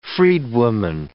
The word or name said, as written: freedwoman